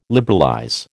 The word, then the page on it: liberalize: American English